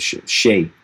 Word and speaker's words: chai: They call it CH, like chai, but I think they call it shay. Shay is how they pronounce it.